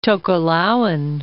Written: Tokelauan

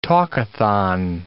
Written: talkathon

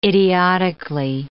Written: idiotically

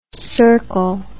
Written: cercal

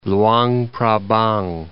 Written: Luang Prabang

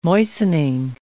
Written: moistening

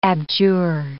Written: abjure

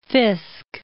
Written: Fiske

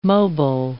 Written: mobile

